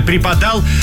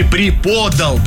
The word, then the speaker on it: преподал: Да, ну и в конце вы сказали преподал, но все-таки это неправильно. Надо было произнести преподал.